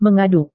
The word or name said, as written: mengaduk